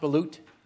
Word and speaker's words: balut: Go through it because I want to learn how to make a good balut. Well, yeah, you come down this way because they won't mind you stepping on them. All right. Is it pronounced balut? – Yes.